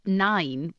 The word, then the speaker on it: Nain: Nine, which is spelled N-A-I-N, means grandmother.